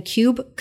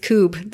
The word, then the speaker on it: kube: And the control or the command that you run to then ask some of the questions to Kubernetes is kube control, but it's spelled K-U-B-E. So I kept saying kube control, and Joe's like, it's kube. The cool kids say kube.